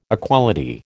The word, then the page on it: equality: American English